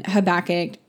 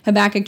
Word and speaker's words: Habakkuk: We are, and I've been saying this wrong the entire episode. I am actually focusing on Habakkuk uh, chapter 2, 19. Okay, let me say that again. Habakkuk chapter 2, verses 19 and 20.